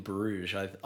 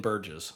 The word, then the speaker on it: Bruges: I always thought, when I saw the movie, it was like, I never knew it was pronounced in Bruges. For the longest time, I thought it was in Burgess. – Burgess, because that's how it's spelled.